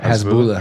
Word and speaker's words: Hezbollah: So, to describe Hezbollah, am I saying his name right? – Hezbollah. – Hezbollah? Sorry, Hezbollah.